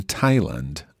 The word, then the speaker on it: Thailand: se, escribe T-H-A-I-L-A-N-D, pero se pronuncia Thailand. – Thailand. – Thai, con T, ¿ok? Thailand. – Thailand. – Muy bien. Ok, normalmente TH como think se, se pronuncia como th, think. – Think. – O como gracias, thank you. – Thank you. – Pero el nombre de este país se dice Thailand.